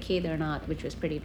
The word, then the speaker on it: Kedarnath: Am I saying that right? – Kedarnath, yeah.